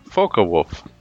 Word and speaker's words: Focke-Wulf: The Focke-Wulf, pronounced Focke-Wulf, was a German aerospace company named partially after founder Heinrich Focke that operated from 1923 to 1964.